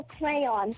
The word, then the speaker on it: crayon: The Y is silent. It is crayon. – Thank you. It is a silent Y.